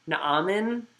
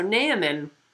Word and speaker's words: Naaman: So my understanding of Hebrew is that this person's name should be pronounced Naaman,